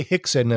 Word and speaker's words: Rickson: So we say Hickson, and that's the Portuguese pronunciation. It's spelled Rickson, right? – Yeah. – Rickson, it would be Rickson and Relson and Ralph. – Yeah. – It's Hickson and Helson and Half.